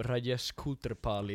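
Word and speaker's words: Rajesh Koothrappali: Jag är som Rajesh Kutrapal i Big Bang Theory, jag kan inte ens prata med tjejer.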